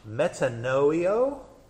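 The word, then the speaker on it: metanoia: And a couple of those examples, Jerome's translation of the Greek word metanoio. How do you pronounce that? – Metanoio. – Metanoio? Was a mistake.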